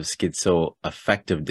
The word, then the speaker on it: schizoaffective: So I said you have schizoaffective disorder.